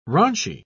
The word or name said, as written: raunchy